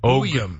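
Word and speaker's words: Buium: Which was Boyum or Beum, however you properly pronounce it. – Boyum.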